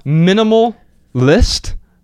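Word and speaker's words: MNMLIST: So it's called Minimal List. Now, it is spelled MNMLL.IST. So it's like minimal without the vowels, only the consonants, with an L at the end, .IST. Minimal List.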